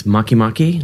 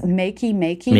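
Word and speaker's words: Makemake: So you're saying it's Maki Maki? Not. – I think it's Makey Makey. Makey Makey rhymes with bake and right. – Bake. – Right. Makey Makey. – Right. It's not a fish dish.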